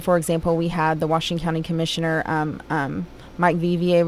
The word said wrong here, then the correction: Bouvier: was... I'm sorry, I probably butchered his name. – Real close, Bouvier, yeah.